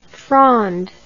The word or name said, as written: Fronde